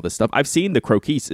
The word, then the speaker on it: Croquis: I think it's pronounced like Marquise, right? Like Crokies. – Crokies. – Mm-hmm. – Not Crokweis. – Not Crokweis.